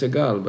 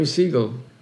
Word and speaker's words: Segal: And the right way to pronounce my name is Segal, but coming to America, where names are being distorted a little bit, so I became a Segal.